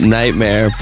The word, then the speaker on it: NGHTMRE: It's at Nightmare, but it's spelled N-G-H-T-M-R-E.